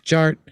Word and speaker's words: DJ Art: And my name is Jared, aka DJ Art, with two T's for a double dose of that tink tink. The D is silent, so it's just Jart.